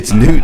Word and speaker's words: Newton: It's Newsom? Am I saying Newton? – It's Newton. – I just now caught that. I thought you'd been saying it right, but I've been saying. What did I say? – You've been saying Newsom. It's Hunter Newton.